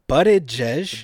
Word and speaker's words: Buttigieg: Buttigieg? – Buttigieg.